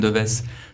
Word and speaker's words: Deves: Yeah, it's it's I would say the correct Czech pronunciation for my name. If I if I pronouncing the French way, Aurélien Deves.